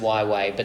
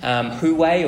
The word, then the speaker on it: Huawei: And so the Huawei, you know, that's actually spelled H-U-A-W-E-I. I didn't know that it was pronounced Huawei, but that's how it's actually pronounced. If you see it and you've made up the name in your mind, Huawei, or whatever, it's actually Huawei.